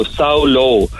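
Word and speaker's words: Samhlú: Saulu is spelled S-A-M-H-L-U follow, so Saulo.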